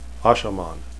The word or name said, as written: ashaman